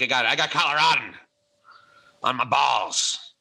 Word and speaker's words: Coloradan: And how do you say that? Coloradan? – Coloradan, yeah.